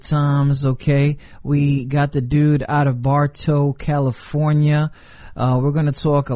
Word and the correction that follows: Barstow: a lot about what the thing, did I say that wrong? Barstow? There you go. Barstow, California.